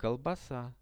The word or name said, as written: kolbasa